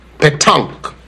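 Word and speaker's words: pétanque: Oh, no, that's not the correct pronunciation. It's tonk. – Dear, it's paid tonk. – No, Cliff. The proper pronunciation is petonk.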